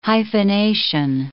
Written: hyphenation

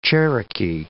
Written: Cherokee

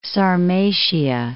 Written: Sarmatia

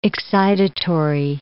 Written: excitatory

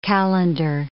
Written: calendar

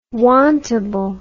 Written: wantable

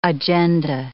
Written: agenda

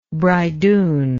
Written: bridoon

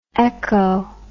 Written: eco

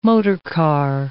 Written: motorcar

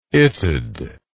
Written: itd

